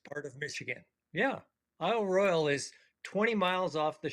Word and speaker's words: Royale: I think I mispronounced it, Isle Royale. You said it has an E on the end, so what's the deal? – It has the E, but it's only pronounced Royal.